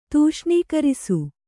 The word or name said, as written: ತೂಷ್ಣೀಕರಿಸು